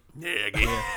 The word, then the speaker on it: Nagy: I know there's a lot of weapons in Chicago right now, but Matt Nagy, is that how you say it? – Nagy.